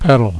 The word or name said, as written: petal